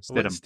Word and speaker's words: Stidham: You don't like to pronounce the H, do you? Stidham. – Stidham. Like, why do you do that? Why? Just say Stidham.